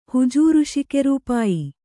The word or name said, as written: ಹುಜೂರುಶಿಕೆರೂಪಾಯಿ